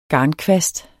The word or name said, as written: garnkvast